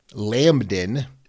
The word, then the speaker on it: Lambdin: Lambdin is spelled L A M B D I N. So, like a lamb, D I N, Lambdin Milligan.